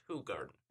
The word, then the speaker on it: Hoegaarden: and it's actually spelled Hoegaarden. like the word hoe and the word garden. Nice. But it's pronounced hoe garden.